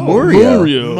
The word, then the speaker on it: Mario: So it's actually pronounced Mario. – Oh, Mario. – Mario. – Is that true? – It is actually Mario. Pronounced Mario. – Mario. – Oh, so A is like a schwa.